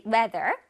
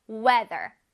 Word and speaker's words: weather: weather. weather. weather. weather. 바로 날씨, 기상이란 뜻이에요. 날씨, 바로 weather. Okay? 자, 우리 이거 한번 발음해 보겠습니다. weather. weather. 좋습니다. TH가 있어서 혓바닥에 앞으로 나왔다가 뒤로 슝 들어가죠?